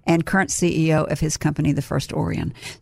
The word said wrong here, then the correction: Orion: So did I say it right? – No. Orion. – Oh, I said it wrong again. Sorry. Well, it's like Orion's belt.